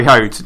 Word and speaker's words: Foyth: But there's also Foyth. Sorry, Hoat, just another one syllable name.